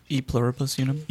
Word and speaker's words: E Pluribus Unum: This week, just joined me in talking about Stranger Things Season 3, Episode 6, In Pluribus Unum. Did I say that right? – E Pluribus Unum.